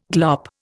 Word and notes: glop: Indian

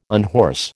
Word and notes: unhorse: American English

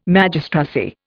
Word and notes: Magistracy: Indian

